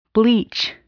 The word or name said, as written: bleach